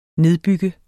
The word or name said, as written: nedbygge